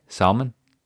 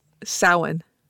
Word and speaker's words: Samhain: Yeah, what was the actual pronunciation again? Sorry, Salmon? – Samhain. – Samhain.